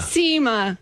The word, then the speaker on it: Cima: Cima.